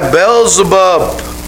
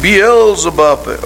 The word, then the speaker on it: Beelzebub: By Beelzebub. Or some people, they pronounce it Beelzebub.